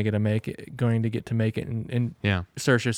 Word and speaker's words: Saoirse: The reason I know how to say her name is on talk shows, when Lady Bird came out, she'd say, "It's Saoirse, like inertia."